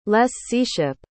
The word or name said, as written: lesseeship